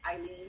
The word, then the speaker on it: Eileen: My name is Eileen Hill. Yes, the A is silent.